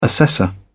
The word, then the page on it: assessor: British English